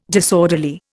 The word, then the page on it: disorderly: Indian